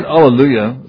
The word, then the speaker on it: Alleluia: The word Alleluia, sometimes we pronounce it Alleluia with an H, but it's based on Hallel, which means praise.